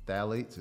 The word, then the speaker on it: phthalates: So when you turn it on the label and you see phthalates, it starts with a P, mm-hmm. the P is silent.